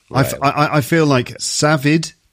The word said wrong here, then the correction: Sajid: I always get his name wrong. – It's Sajid.